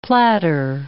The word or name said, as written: platter